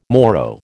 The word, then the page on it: morrow: American English